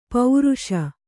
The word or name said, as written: ಪೌರುಷ